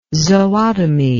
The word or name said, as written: zootomy